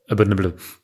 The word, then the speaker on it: Abominable: Heffy hat es ja schon gesagt, ist auch Produzent und auch beim Titelsong und Abominable, warte, wie wird das ausgesprochen? Abominable? – Abominable. – Ja, genau, da